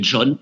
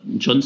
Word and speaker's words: John: Et Rocher, ça s'écrit R-A-U-S-C-H-E-R. E et John, John c'est le prénom dans mon prénom, c'est le prénom anglais J-O-H-N.